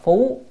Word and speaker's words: phú: Cái chữ này đọc là phú thì là che trùm, còn đọc là phúc là lại. Đây mình đọc phú, che lên.